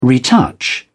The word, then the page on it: retouch: British English